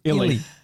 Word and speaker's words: Illy: Illy. I-L-L-Y.